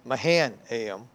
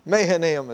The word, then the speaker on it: Mahanaim: Now, locally, we would pronounce the name of that place Mahanaim, but Mahanaim is probably correct.